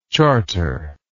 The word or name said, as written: charterless